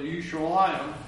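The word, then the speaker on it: Yushalayim (ירושלים): It's actually Yushalayim, which is the Hebrew pronunciation of Jerusalem.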